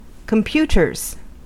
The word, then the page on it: computers: American English